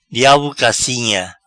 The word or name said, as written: Diabo cacinha